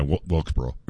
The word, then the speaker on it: Wilkesboro: Okay, ignoring that, if he didn't win back in North Wilbrook, so is that how you say it? – Yeah, Wilkesboro. – Wilkesboro.